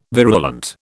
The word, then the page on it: virulent: British English